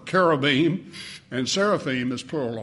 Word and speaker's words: seraphim: They're also in the Bible in the form of those two words that many people mispronounce, cherubim and seraphim. Familiar with those words? I've heard people say cherubims and seraphims, but the I-M on the end of those words in the Hebrew is plural already.